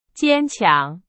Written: 坚强

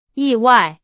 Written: 意外

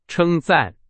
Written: 称赞

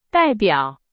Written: 代表

